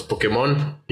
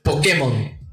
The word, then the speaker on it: Pokémon: Porque aquí en Hispanoamérica decimos Pokémon y ponemos el acento, güey, en el, la O para el ON. Cuando es Pokémon. Sí, los españoles tienen razón, es Pokémon.